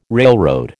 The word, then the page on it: railroad: American English